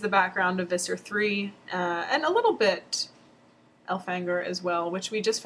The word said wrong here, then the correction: Elfangor: found out when we were Wikipedia-ing pronunciations that K.A. herself has said that it's pronounced Elfangor, which...